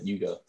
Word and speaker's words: Hugo: Hugo, but Hugo in French. – Hugo. – You don't pronounce the H. Hugo. Hugo, really.